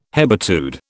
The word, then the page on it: hebetude: British English